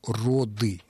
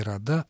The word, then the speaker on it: роды: форму «роды», не «рода», а «роды».